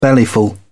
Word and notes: bellyful: British English

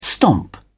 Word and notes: stomp: British English

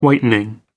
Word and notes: whitening: American English

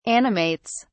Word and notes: animates: British English

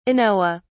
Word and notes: anoa: British English

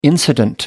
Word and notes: incident: American English

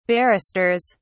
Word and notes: barristers: British English

